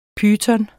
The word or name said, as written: PYTONER